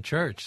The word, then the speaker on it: Schürch: Mummenschanz was formed in the 70s in Paris between Andrés Bossard and Bernie Schürch, or Church, as you wish. – Well, I asked him how to pronounce his name, and he said Church.